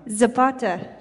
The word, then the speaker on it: Zapata: Zapata.